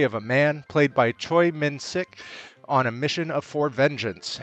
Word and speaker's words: Choi Min-sik: I think his name is pronounced Choi Min-sik.